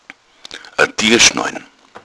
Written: tuerschnalle

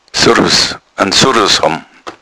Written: surrus

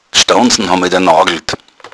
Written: staunzn

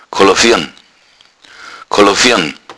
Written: kolloviern

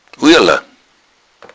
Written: uiala

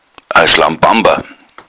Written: schlampamper

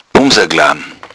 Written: bumser

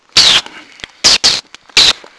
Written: bussi

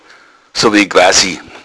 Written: quasi

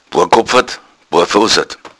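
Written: barfüßig

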